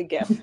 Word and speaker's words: GIF: Do you pronounce it GIF or JIF? – It's absolutely GIF. – Right answer. – It is absolutely GIF.